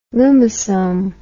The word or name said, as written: lumisome